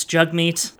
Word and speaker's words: Jagmeet: It's not pronounced Jagmeet, it's Jagmeet.